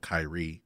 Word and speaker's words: Kyrie: Kyrie, which is spelled Kyrie, but it's pronounced Kyrie, and Broken Wings.